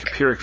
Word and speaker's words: Pyrrhic: First, why does Magneto call the victory against Stryker and I always mispronounce it, Pyrrhic? – Pyrrhic victory. So a Pyrrhic victory is one that is offset by staggering losses.